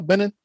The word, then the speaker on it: Benin: Benin? B-E-N-I.